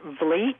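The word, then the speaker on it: Vliet: And just to clarify, my name is pronounced Vleet, like fleet.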